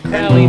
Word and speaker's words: Crowley: And Crowley never even fucking knew shit about Jack Carson. Even though Thomas Jefferson, where are you now? – Crowley rhymes with Howley.